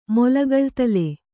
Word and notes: ಮೊಲಗಳ್ತಲೆ: Kannada

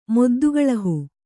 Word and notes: ಮೊದ್ದುಗಳಹು: Kannada

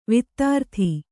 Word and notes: ವೈಧೃತಿ: Kannada

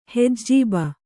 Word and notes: ಹೆಜೀಬು: hejību

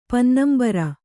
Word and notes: ಪನ್ನಂಬರ: pannambara

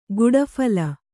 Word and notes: ಗುಡಫಲ: guḍa phala

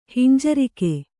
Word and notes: ಹಿಂಜರಿಕೆ: hinjarike

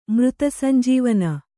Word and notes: ಮೃತಸಂಜೀವಿನಿ: Kannada

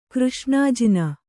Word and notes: ಕೃಷ್ಣಜೇನು: křṣṇa jēnu

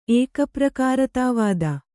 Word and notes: ಏಕಪ್ರಕಾರತಾವಾದ: ēkaprakāratāvāda

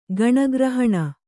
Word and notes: ಗಣಗ್ರಹಣ: gaṇagrahaṇa

